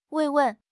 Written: 慰问

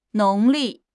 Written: 农历

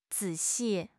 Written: zi3 xi4

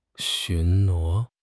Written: 巡逻

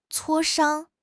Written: 磋商

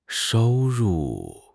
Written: shou1 ru4

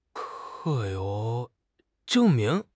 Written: zheng4 ming2

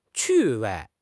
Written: 趣味

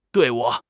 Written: 队伍

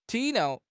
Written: 体谅

